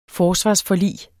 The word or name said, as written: forsvarsforlig